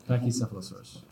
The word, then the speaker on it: Pachycephalosaurus: I'll be going with the Pachycephalosaurus. Pachycephalosaurus. How do you say that? – Pachycephalosaurus.